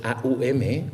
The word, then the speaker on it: OM: entre ellos la sílaba OM, que en sánscrito es AUM,